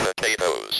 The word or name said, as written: potatoes